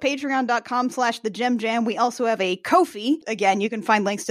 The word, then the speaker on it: Ko-fi: I feel like it's supposed to be pronounced coffee, but also they spell it like Kofi Annan, the old general secretary of the UN, so it's Ko-fi.